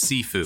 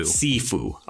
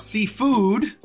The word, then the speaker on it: Sifu: It is pronounced Sifu. – Sifu. Okay. – Thank you. Tensei. – My mistake. It is pronounced Sifu. Thank you. – Not seafood. Not sushi, bros. Sifu. – It is spelled S-I-F-U.